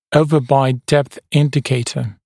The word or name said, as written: overbite depth indicator ODI